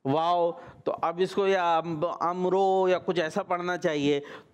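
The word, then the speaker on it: अम्र (عمرو): तो इसी तरीके से ये वाव जो है ये साइलेंट है और इसको पढ़ेंगे हम अम्र।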